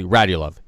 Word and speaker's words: Radulov: But I think it's something almost rhymes with Markov. But it's actually Radulov.